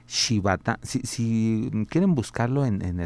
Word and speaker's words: Shibata: es eh, T-O-S-H-I-Y-U-K-I, Toshiyuki, y el apellido es Shibata, S-H-I. B-A-T-A, Toshiyuki Shibata.